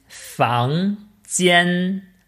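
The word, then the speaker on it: Fang Jian (房间): Ich würde sagen, äh, Zimmer ist Fang Jian, also F-A-N-G, J-I-A-N, Fang Kätzchen.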